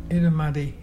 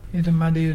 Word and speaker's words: irrumardi: Edamuddy. – What is a... – Edamuddy is brim, the fish brim, and it's spelt IRRUMARDI. Can you say Edamuddy?